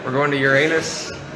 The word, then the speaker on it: Uranus: Uranus. It's the correct pronunciation.